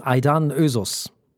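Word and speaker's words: Aydan Özoğuz: Die wird nämlich ausgesprochen Aidan Ösus. Ah. So wird die gute Frau ausgesprochen. Ich habe ja was ganz anderes da erzählt. Das war natürlich vollkommener Blödsinn. Also unsere Vize-Bundestagspräsidentin heißt Aidan Ösus.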